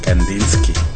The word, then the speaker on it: Kandinsky: Kandinsky o Kandinsky, ¿cómo se pronuncia? – Kandinsky.